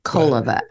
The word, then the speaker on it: Koleva: Her name at that time was Eva Koleva. – Koleva, actually. – Koleva, I guess I have my pronunciation.